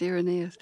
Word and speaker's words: Irenaeus: Now, you credit Irenaeus, am I saying his name right? – Yes, Irenaeus.